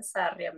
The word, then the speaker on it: Sarria: É, sim, porque é em espanhol originalmente, né? Então é Sária mesmo.